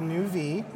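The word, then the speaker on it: NeuV: It's pronounced the new V, which makes me personally want to die.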